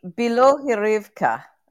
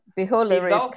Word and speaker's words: Bilohorivka: B-I-L-O-H-O-R-I-V-K-A. – Yeah. – Yeah. – I know. – Yeah. – Belohirivka. – Belohirivka.